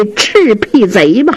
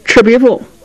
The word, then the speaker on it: 赤壁赋: 来来来来，劳你大驾，我这有把扇子，这边是素的，你也给我写上这赤壁贼吧。雨墨不敢公开的顶了，小声嘀咕，连字儿都不认识，赤壁赋念成赤壁贼了，真不是东西。嗯，真不是东西，这句话呀声音稍微大这么一点，这冯京衡好像听见了，一歪头，严茶散呢就给岔活过去了。